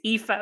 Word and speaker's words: Aoife: So I tell people to read it backwards. So it's pronounced as if it's EFA, but it's spelled AOIFE.